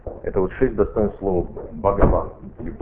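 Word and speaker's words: Бхагаван (भगवान): Бхагаван. Это санскритское слово. Бхагаван состоит из двух частей. Бхаг – это Бог. Ха в санскрите не читается, помните, я вам рассказывал.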